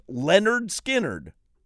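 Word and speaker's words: Lynyrd Skynyrd: There's a Leonard Skinner album that's called, it's pronounced Leonard Skinner.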